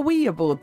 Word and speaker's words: Ouija: Ouija. – Why don't we call it a Ouija board, then? – Well, apparently people do, but we do the hard J around here.